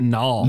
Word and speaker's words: Nall: So in the beginning, characters, you have Alex, Luna, and is it Nail or is it Nall? – No, I would have said Nall. – I always said Nall too, but it's spelled like Nail, right? – N-A-L-L. – Is it N-A-L or I thought it was N-A-I-L? – Oh, I think it's N-A-L-L. – I'm pretty sure it's N-A-L-L.